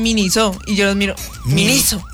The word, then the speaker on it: Miniso: ¿Se dice así? – ¿Por qué? ¿Cómo es? – Miniso. – Miniso. Ah, bueno.